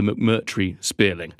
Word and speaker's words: McMurtry Spéirling: I am, of course, talking about the McMurtry Spéirling.